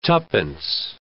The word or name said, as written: twopence